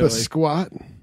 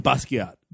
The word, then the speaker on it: Basquiat: Basquiat? Is that, no, I'm saying it wrong. – Basquiat. – There we go.